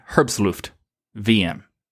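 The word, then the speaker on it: herbstluftwm: This would probably be a very good one to be on there, but if you're wondering, again, Herbstluft. VM is how you say this one.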